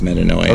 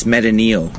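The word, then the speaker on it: Metanoia (μετάνοια): It's not even pronounced Metanoia. – Okay. That's fair. – Pronunciation. How would you pronounce it? – It's Metanoia. – Okay. – So it is Metanoia.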